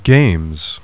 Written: games

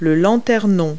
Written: lantern

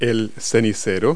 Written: ashtray

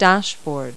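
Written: dashboard

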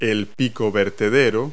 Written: spout

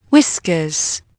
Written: whiskers

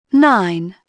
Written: nine

